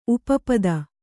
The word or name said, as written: ಉಪಪದ